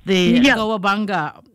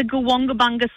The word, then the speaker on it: Goobunga: Goobunga program. – You're very, very close. So it's the Goobunga Swim Program.